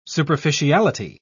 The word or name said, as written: superficiality